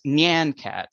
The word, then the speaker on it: Nyan Cat: So it's Nyan Cat. I know a lot of people out there think it's like Nyan Cat or other ways, but it's a Nyan Cat.